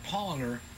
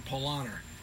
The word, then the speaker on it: Paulaner: in English, you'd pronounce it Paulaner. In German, they pronounce it Paulaner. – Paulaner. – P-A-U-L-A-N-E-R.